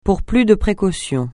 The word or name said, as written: pour plus de précaution